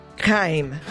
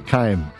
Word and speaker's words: Chaim: W-E-I-T-Z. – Then it's Weitz. – Okay, Weitz. – Right. – And I think you pronounce Chaim Keim. – Keim. – There you go. – C-H-A-I-M.